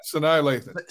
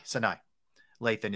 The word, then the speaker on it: Sanaa Lathan: Sana Lathan, I believe that's the correct pronunciation. Excellent actress. 52. Yeah, Sanaa Lathan. – Sanaa Lathan is 52 years old.